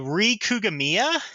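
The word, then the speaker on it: Rie Kugimiya: Rie Kugamiya, I believe is how you say her name. Yes. Okay. Or at least that's how it's spelled.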